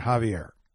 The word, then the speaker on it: Xavier: However you want to pronounce X-A-V-I-E-R. You got two choices. Xavier and Javier.